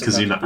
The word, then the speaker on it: Kizuna: That's just Kizuna AI. To VTuber. I am a Kizuna AI fan. Leave me alone. – Well, you said it wrong, so I just corrected you.